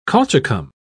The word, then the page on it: colchicum: British English